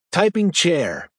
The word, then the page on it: typing chair: American English